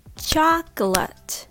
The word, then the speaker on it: chocolate: As you know, in many other languages, it has four syllables like chocolate or chocolate in English, the word originally has three syllables chocolate. But the middle syllable is a reduced syllable, so often people just skip it. So they say chocolate. So the first syllable is chalk, chalk. And the second syllable is ult, ult chocolate chocolate chocolate